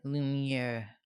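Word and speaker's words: Lumière: Lumiere.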